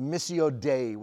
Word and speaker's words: missio Dei: There's an old Latin theological term that's pronounced missio Dei, which simply means the mission of God.